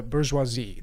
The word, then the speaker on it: bourgeoisie: So you have the proletariat, the worker, and you have the bourgeoisie, the owner of the means of production.